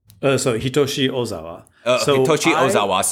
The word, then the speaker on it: Hitoshi Ozawa: Hitoshi Ozawa.